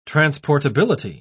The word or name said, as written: transportability